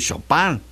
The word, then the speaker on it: Chopin: Al igual que con el de Federico Chopin, tenemos que decir Chopin.